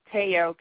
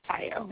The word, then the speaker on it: Tayo: Yeah, most people pronounce it Teo because, you know, but it's actually, like you said, it's pronounced Tayo. It's Nigerian.